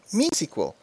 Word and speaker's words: MySQL: One, it's either MySQL, or not MySQL, or more appropriately, MeSQL, because MY is actually one of the founder's daughter's name.